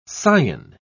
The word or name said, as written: scion